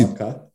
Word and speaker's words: maca: A ja, pa mimo grede, promogu sem jih preveriti, ali se izgovori maca ali maka. Okay, kako nisi, maka.